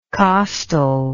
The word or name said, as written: costally